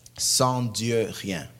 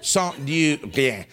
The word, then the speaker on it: Sans Dieu rien: Sans Dieu rien. – Oh, so I didn't say it right. Sans Dieu rien. Rien. It's got this sound to it. Sans Dieu rien.